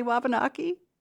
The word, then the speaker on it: Wabanaki: How do you pronounce the word? Have I been saying it wrong all these years when I say Wabanaki? – No, I think so. It comes from the word Shkwabanakiig, which means people of the dawn, and that comes from our language. So I think, you know, just taking a chunk of that Wabanaki, everyone's going to say it probably a little bit differently.